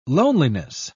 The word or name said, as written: loneliness